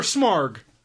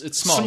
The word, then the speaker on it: Smaug: Is it Smaug or Smarg? – It's Smaug. – It's Smaug. There's no R.